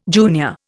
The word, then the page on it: junior: Indian